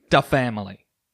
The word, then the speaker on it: Da Family: Originally, she's on Blackground Records and Da Family.